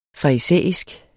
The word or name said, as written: farisæisk